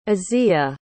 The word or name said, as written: azia